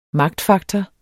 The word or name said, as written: magtfaktor